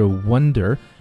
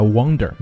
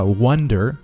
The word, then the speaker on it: wander: merely 这个词呢，就表示仅仅的，相当于是 onlyw ander through 啊， wander，注意这个词它的发音不是 wonder 啊，英音也可以发 wonder，没有问题啊，美音发 wander，注意这个口型的话要大一些，表示漫游闲逛。